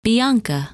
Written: Bianca

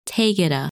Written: Taygeta

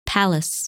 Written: Pallas

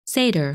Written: Sadr

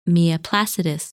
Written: Miaplacidus

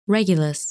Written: Regulus

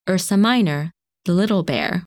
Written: Ursa Minor

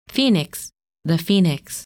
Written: Phoenix

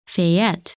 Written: Phaet

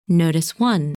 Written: Nodus I

